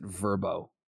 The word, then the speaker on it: VRBO: I also thought it was VRBO. I never knew they pronounced it Verbo.